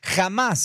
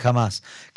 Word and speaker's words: Hamás: Sí, lo que Samir dijo fue, Hamás será derrotado. Es que la H se pronuncia como J.